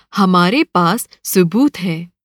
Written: सुबूत

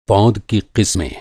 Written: पौध

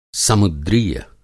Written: समुद्रीय

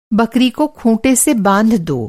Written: खूँटा

